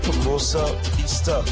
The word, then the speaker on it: Hermosa Vista: All right, I was just having a nice little chat with one of our friends from, is it Hermosa Vista? Did I pronounce that right? Yes. Hermosa Vista.